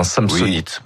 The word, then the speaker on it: Samsonite: Faut le prononcer à la française, hein, Samsonite.